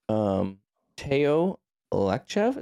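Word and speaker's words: Teo Lachev: So the article, I believe this is from Teo Lekchev. Is that how you say his name? – Yep, Teo from ProLogica.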